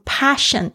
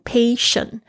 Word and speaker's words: passion: Passionate, 它是从 passion 这个字来。 – 大家特别注意一下你们 A 的发音，不要念成 patient. Patient 会变成就是有耐心的。 Passion 这个 A 是那个就是舌头向下压，那个音标看起来像蝴蝶的那个声音。